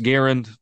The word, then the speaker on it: Garand: At this point, the warriors are going to come at me and say it's pronounced Garand, but I'm saying what I'm saying.